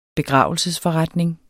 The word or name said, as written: begravelsesforretning